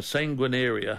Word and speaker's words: sanguinaria: And one of those herbs is a herb called sanguinaria. I'll spell it for you. S-A-N-G-U-I-N-A-R-I-A, sanguinaria.